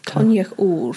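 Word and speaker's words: Coinneach Odhar: Conaoch Orr.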